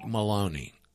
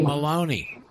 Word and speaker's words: Maloney: Mike Maloney. – Yeah, I know. I did. I said he. Let me go back in the bathroom. Michael Mulroney. I can't say his name. – Maloney.